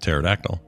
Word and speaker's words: pterodactyl: I mean, my initial thought is usually that the first letter is never silent, but that's not true, as you just explained with pterodactyl.